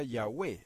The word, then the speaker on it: Yahweh: O Yod-He, Vav-He, que sería en español YHWH. O-Y-H-V-H. Y se pronuncia Yahweh.